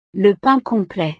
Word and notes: le pain complet: frances

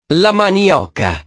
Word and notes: la manioca: italiano

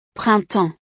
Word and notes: printemps: frances

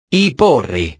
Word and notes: i porri: italiano

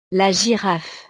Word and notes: la giraffe: French

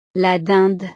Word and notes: la dinde: frances

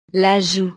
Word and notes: la joue: French

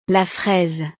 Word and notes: la fraise: French

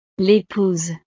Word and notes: l'epouse: frances

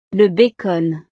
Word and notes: le bacon: frances

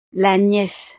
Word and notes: la niece: frances

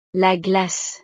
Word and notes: la glace: French

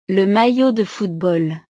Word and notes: le maillot de football: frances

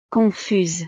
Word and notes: confuse: frances

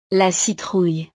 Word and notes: la citrouille: frances

